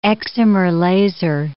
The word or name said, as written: excimer laser